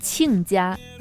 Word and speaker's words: 亲家: Chinja. – But no, Ah. – it's pronounced 亲家. This 亲 in Chinese has two pronunciations.